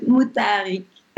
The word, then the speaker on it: Moutaharik: Es Mutarik.